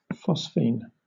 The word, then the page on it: phosphine: British English